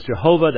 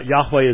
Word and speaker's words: Yahweh: But that is the Tetragrammaton, the name of the covenant God of Israel, Yahweh, which we slaughter in English as Jehovah. That it could not have been pronounced as Jehovah, but Yahweh is the best pronunciation.